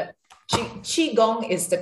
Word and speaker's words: Qigong: It's actually Qi. Qi. It's Q-I, but okay. it's often mispronounced, but that's the pinyin version. So it's not important. C-H-I, I think in English, is the closest sound to Qigong.